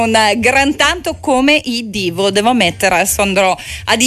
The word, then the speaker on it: Devo: Clock Out, loro sono i Devo, anzi, si pronuncia Devo o Divo? Chiedo a te. – Si pronuncia Divo. – Divo, perfetto. Ammetto subito la mia ignoranza sulle pronuncia di determinati gruppi.